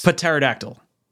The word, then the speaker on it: pterodactyl: The P is silent. – It's pterodactyl.